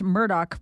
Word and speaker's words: Murdaugh: It's pronounced Murdaugh by locals and the family.